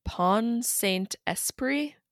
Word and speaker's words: Pont-Saint-Esprit: In 1951, a case of ergot poisoning was recorded in France in the town of Pont-Saint-Esprit. I don't know if I'm saying that right. – Sounds right to me.